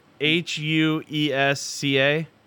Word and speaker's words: Huesca: H U E S C A.